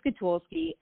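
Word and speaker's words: Szkatulski: So it's Lisa Skatulski, S-Z, like zebra, K-A-T-U-L-S-K-I.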